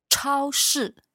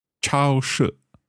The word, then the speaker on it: 超市: 超市. – 超市.